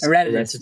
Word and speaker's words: Herodotus: Herodotus? – Herodotus, yeah.